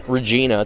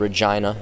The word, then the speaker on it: Regina: It's not Regina. It's called Regina.